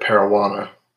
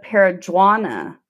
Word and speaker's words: Paragonah: Well, the controversy is whether that G is a hard G or a soft G. – Yeah, I think it's a Spanish G. So I pronounce it Parowana. – Oh, see, and I've always said Paraguana.